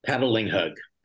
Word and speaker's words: Patalinghug: Paddling Hug.